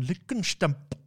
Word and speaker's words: Lickenstamp: And Niles from Frasier turned up as a villain known as Baron von Lickenstamp. – Beautiful. – It was amazing. – Beautiful. – Again, you're allowed to say that because you're German. – Lickenstamp. No, it's how they pronounced it in the cartoon.